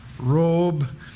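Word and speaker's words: robe (רֹב): In the multitude, the many, the great, it's an indefinite number of a large quantity, R, long O, B, robe, and it's used 147 times in the Old Testament.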